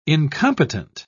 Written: incompetent

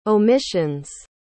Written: omissions

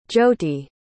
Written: jyothi

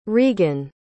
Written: reegan

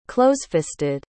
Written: closefisted

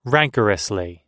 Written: rancorously

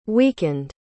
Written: weakened

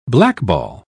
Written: blackball

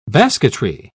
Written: basketry

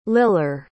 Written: liller

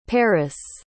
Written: parous

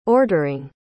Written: ordering

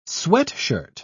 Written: sweatshirt

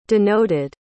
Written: denoted

